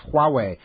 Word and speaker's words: Huawei: But the Huawei ban, am I saying it right? – Huawei.